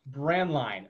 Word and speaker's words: Braendlein: Braendlein.